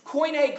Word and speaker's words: Koine: Koine Greek is how it's pronounced.